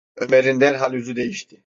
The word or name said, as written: derhal